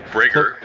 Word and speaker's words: Brager: Brager.